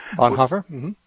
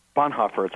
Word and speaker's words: Bonhoeffer: But, you know, was Dietrich Bonhoeffer, I pronounced his name wrong. – Bonhoeffer? – Bonhoeffer, thank you.